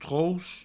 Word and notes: troc: trouos

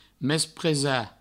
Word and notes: mespresar: mespresa

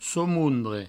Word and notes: somondre: soumoundre

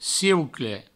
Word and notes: ciucle: cieucle; provençal

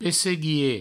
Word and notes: pesseguier: Provençal; pesseguie

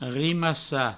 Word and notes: rimassar: rimassa; Provençal

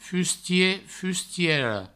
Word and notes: fustier, fustiera: fustie, fustiero

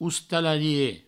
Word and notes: ostalarie: oustalarie

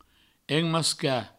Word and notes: emmascar: enmasca

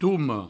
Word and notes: toma: toumo

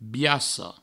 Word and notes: biaca: Provençal; biasso

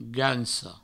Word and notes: gansa: ganso

